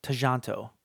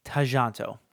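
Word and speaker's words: Tjahjanto: However, the way that you pronounce it, according to Wikipedia at least, is Timo Tajanto. Tajanto. So the first J is sort of silent, but the second J you actually pronounce.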